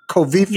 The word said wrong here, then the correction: Kofifi: Yeah. it's pronounced Kofifi.